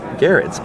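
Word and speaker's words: Gerads: I got here Tom King and Mitch Gerards. It's pronounced Gerards, right? – Garrett's.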